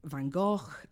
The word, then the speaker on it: Van Gogh: Monet, Manet, Van Gogh.